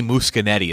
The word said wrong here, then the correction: Muschietti: Is that how you say his name? – Muschietti, there you go.